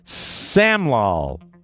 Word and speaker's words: Samlal: Last week, 47-year-old Nardeo Samail. – How do you say the name? – S-A-M-I-A-L. Samlal. S-A-M-L-A-L-L. Nardeo Samlal of Toronto.